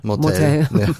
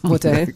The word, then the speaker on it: Motel: 1984 bist du Teil einer Serie geworden, einer Schweizer Erfolgsserie, die auch 2020 wiederholt wurde, Motel. Oder nee, man kann es, glaube ich, Deutsch aussprechen. – Motel. – Motel.